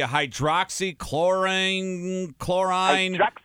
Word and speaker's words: hydroxychloroquine: It's for malaria, and it's the hydroxychloroquine.